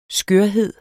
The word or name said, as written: skørhed